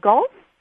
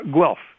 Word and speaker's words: Guelph: In how do you pronounce the name of the town? Gulf? – Guelph. G-U-E-L-P. Guelph.